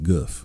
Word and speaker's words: Gulf: sometimes you pronounce it differently, you know, like goof. It's the goof.